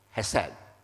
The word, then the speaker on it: hesed: Now the first letter has the sound of H. And you see the three dots there. These are vowels. And the vowels is pronounced as eh, as in egg. So it's he, and then the second letter is s, sounds, and then again the e. Oh, he, se, then the last one is pronounced with d. So it's hesed.